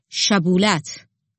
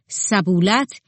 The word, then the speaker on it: شبولت: اگر می‌گفت نی نیستم، آن وقت نگهبانان می‌گفتند: بگو شبولت. اگر به عوض شبولت می‌گفت سبولت، یعنی کلمه را به درستی تلفظ نمی‌کرد، آنگاه او را می‌کشتند.